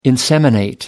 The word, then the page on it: inseminate: American English